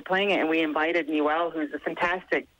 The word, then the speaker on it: Niwel: Do you pronounce it Newell? N-I-W-E-L. Newell Goes to Town. – Yeah, Newell, yeah.